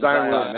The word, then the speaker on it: Zion: Zion.